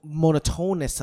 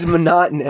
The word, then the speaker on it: monotonous: We find it tedious to watch because we see the monotonous of it. But for them, every single... What? – It's monotonous. – I said it wrong? – You said it wrong. It's monotonous.